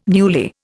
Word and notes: newly: Indian

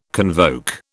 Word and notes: convoke: British English

